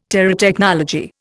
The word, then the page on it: Terotechnology: Indian